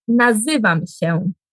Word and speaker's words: nazywam się: Ya nazywam się.